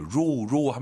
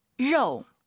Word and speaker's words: 肉: 고기 발음 한번 들어볼게요. 로우 하면 안 돼요, 여러분. – 肉。 – 왜 러우라고 하시죠? 오유 발음은 어우니까. 그렇죠. 3월부터 저희와 함께 하신 분들은 이제 오유는 어우, 러우, 아이유 사이에 역시 오 넣어서 숫자 9, 지우가 아니라 지어우.